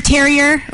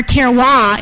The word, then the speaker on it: terroir: Craft beer, has anyone heard the term terrier? Or if you're from the product of the public school system, you might say Terrier or terroir is the proper French pronunciation.